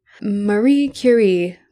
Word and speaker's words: Marie Curie: Marie Curie, which is the only way you should ever pronounce her name. – I mean, that's the way her name deserves to be pronounced. – I mean, she's French, right? You got to say it like that.